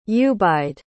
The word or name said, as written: ubaid